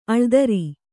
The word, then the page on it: ಅಳ್ದರಿ: aḷdari